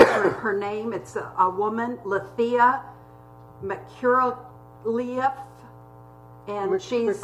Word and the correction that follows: Letha: Did I mess up Letha? – Letha. – Oh, okay. Letha. Yeah, Letha.